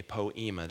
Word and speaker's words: poiema: Uh, that word in the Greek is a word that's spelled P O I E M A, poema.